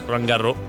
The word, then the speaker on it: Roland Garros: Se fai Roland Garros sono 5, che non si legge la S. Roland Garros.